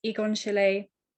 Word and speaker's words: Egon Schiele: And as you say, some people might not have heard of Egon Schiele. Am I saying that right? – You absolutely are.